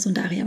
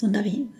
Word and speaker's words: Sundari: Und einer, ich sage mal Sundari, aber es ist Sundari. Die Betonung ist auf dem I.